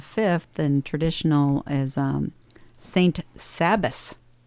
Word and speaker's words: Sabbas: S-A-B-B-A-S. Okay. Sabas.